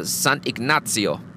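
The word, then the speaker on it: Sant'Ignazio: also hier Einwurf vom Hannes Saint Ignacio di Loyola in Campo Marcio. Achso, ist ja aber eigentlich italienisch. Jetzt habe ich es spanisch ausgesprochen. Also San Ignazio äh, ist die Kirche.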